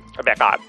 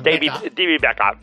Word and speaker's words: Beckham: Pero, ¿Cómo se dice Beckham? – Beckham. – ¿Cómo? David Beckham. – David Beckham. – Eso.